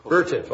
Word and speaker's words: Vertiv: Our next case is Vertiv versus Wayne Burt, number 22-3305. I'm not sure if I pronounced the name of Vertiv properly, but Vertiv.